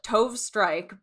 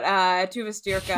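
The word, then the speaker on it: Tove Styrke: I've been like mispronounced. I'm always like Tove Strike, but Tuva Stirka, and I think it's Tuva Lo.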